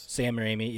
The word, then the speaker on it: Raimi: Sam Raimi, yeah.